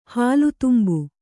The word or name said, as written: ಹಾಲುತುಂಬು